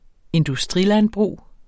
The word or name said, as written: industrilandbrug